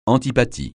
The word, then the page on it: antipathie: francais